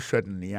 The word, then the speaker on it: certainly: Alors, en parlant de accent, regardez bien pour un mot certainly, accent tombé sur le premier syllabe là.